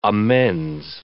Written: amends

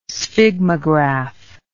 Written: sphygmograph

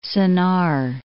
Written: Sennaar

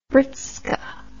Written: britska

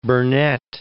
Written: Burnett

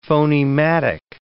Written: phonematic

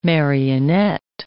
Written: marionette

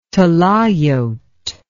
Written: talayot

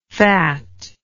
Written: fatlike

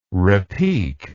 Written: repic

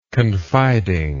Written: confidingness

